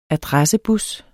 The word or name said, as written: adressebus